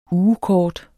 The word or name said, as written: ugekort